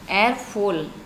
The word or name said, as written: ErpHol